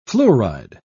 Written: fluoride

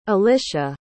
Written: alisha

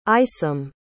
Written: isom

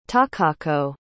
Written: takako

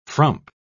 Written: frump

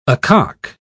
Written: acock

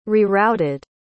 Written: rerouted

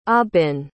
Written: aubin

